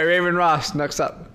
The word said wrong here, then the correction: Raven Raz: Oh, it's Raven Raz, like raspberry.